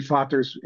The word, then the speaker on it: Vaders: So it's Deze, whereas my name, Vaders, they Americanized it and just kept it. It should be Vaters if you want to honor the original German, but your name sounds like the original French.